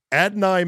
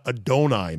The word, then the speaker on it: Adonai: Adonai Mitchell, not Adonai Mitchell, which is I thought that's how they pronounced it. It's spelled that way, but it's Adonai or A.D. Mitchell,